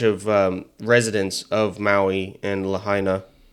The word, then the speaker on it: Lahaina: Even the people in Lahaina? Is that how you pronounce it? – Lahaina? Yeah.